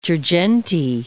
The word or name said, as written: Girgenti